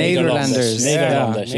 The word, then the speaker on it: Nederlanders: How do you say it? Hollanders, Netherlanders, Nederlanders? – Nederlanders. – Nederlanders.